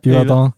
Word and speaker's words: Yverdon: Ja, Yverdon.